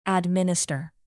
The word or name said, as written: administer